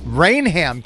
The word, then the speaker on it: Rainham: I am from Rainham. – Rainham, Kennedy. – Is that how you say it? – Yes.